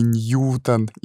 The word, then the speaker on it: Ньютон: Я нашел информацию, что Исаак Ньютон э, — человек с двойным ударением фамилии, поэтому и Ньютон, и Ньютон тон будет правильно.